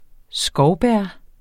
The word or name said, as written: skovbær